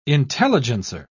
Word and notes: intelligencer: British English

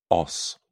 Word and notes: os: British English

